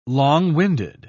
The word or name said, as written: long-winded